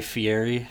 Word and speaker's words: Fieri: I could Guy Guy Fieri.